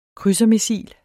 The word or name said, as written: krydsermissil